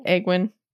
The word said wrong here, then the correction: Egwene: Yeah, I say Egwene. It's Egwene.